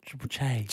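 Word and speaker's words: Triple Chay: No, we can still be triple J, but we spell it triple and then C-H-A-Y. Che? Triple Che? – Triple Che.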